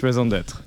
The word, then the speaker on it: raison d'être: It's our raison d'être, as they say. Did I say that right? – Yes, raison d'être.